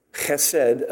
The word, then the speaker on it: chesed: I know it says mercy's sake, but it's chesed again, love Hebrew, love to pronounce it. And if you got a cold, it's great language to speak, just like German.